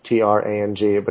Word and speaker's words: Nha Trang: It's written Nha Trang, NHATRANG, but it's pronounced Nha Trang in Vietnamese.